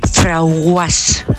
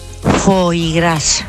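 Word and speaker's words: foie gras: Pues yo tenía una clienta que cuando me iba a comprar cierta cosa decía: dame un frauguas. A ver si sabéis lo que es eso, porque yo el primer día no lo sabía. – ¿Eh? – Foigras. – ¿Qué? – Porque decía que tenía un nombre muy raro y ella no lo sabía pronunciar. Entonces lo decía así: Fraguas. – Fraguas me parece incluso más divertida. – Pero más difícil incluso que el original.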